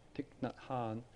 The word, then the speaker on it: Thich Nhat Hanh: Thich Nhat Hanh, which incidentally is spelt T-H-I-C-H, first name, middle name N-H-A-T, and the surname, last name H-A-N-H, Thich Nhat Hanh.